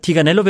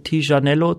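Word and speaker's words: Tignanello: Ähm, Tiganello wird oft falsch ausgesprochen, da sagen oft so welche Tijanello zum Beispiel.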